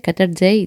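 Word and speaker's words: Qatargate: Πώ προφέρονται, Qatar Gate.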